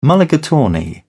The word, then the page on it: mulligatawny: British English